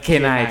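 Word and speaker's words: canine: อันนี้เดียวกันไหมอมอ่าอันเดียวกันล็อกเทปล็อกเทปซ 9 นายไทยแลนด์เออเคนายไทยแลนด์ครับครับเคนายคันนี้จะสะกดว่า C A N I N E นะครับผม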